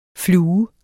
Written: flue

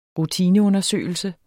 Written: rutineundersøgelse

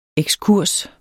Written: ekskurs

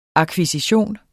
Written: akkvisition

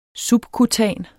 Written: subkutan

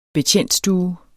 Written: betjentstue

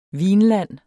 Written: vinland

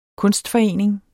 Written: kunstforening

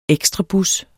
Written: ekstrabus

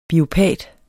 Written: biopat

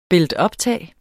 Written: builtuptag

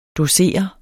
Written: docere

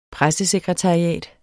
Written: pressesekretariat

